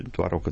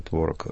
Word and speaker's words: творог: Но в этом случае творог и творог встречаются так часто, что, в общем, можно использовать и то, и другое.